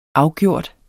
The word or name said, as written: afgjort